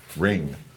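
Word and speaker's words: wring: So rinse, whatever you call that word with a W. Ring. Is it a W? Ring or ring? W-ring. W-ring. It's even harder to pronounce silent W's.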